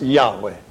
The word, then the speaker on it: Yahweh: So how to pronounce that is another matter. But most say it's pronounced Yahweh.